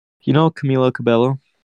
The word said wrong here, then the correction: Camila Cabello: I feel like I'm saying her name wrong. Camila Cabello.